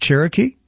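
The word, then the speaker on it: Chiriquí: In Panama, I think it was C-H-I-R-I-Q-U-I, but it's pronounced Cherokee.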